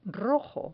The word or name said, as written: rojo